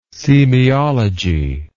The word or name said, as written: semeiology